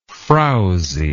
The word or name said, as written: frowsiness